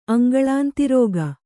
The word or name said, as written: ಅಂಗಳಾಂತಿರೋಗ